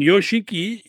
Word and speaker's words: Yoshiki: Mit Y, Yoshiki okay, ausgesprochen.